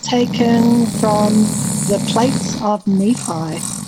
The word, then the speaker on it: Nephi: A lot of people say Nephi, but it's Nephi.